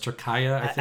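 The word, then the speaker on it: Türkiye: So here's a quote on the a woman named Nilay Kafer, N-I-L-A-Y-C-A-F-E-R. Who is a Turkish beauty from Istanbul, Turkey. Turkaya, I believe. You know, we're changing it. It's not Turkey anymore. It's Turkaya.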